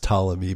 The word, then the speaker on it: Ptolemy: Ptolemy is pronounced Ptolemy, but Ptonomy apparently is pronounced Ptonomy. That's the way they say it in the show.